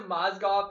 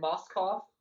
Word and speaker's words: Mozgov: Oh, instead of Mozgov, he said Moskov.